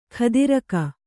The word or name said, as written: ಖದಿರಕ